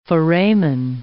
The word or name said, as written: foramen